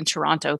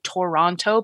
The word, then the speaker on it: Toronto: And now I want to say it like T-R-N-T-O. – Yeah. People tend to remove letters from Toronto. I usually say Toronto, but a lot of people say Toronto or Toronto or something like that. It's just Toronto. – We're lazy. We're lazy with our syllables.